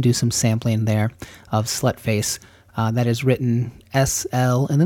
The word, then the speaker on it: Slutface: So S-L, null symbol T-F-A-C-E, but pronounced Slutface.